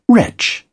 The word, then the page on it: wretch: British English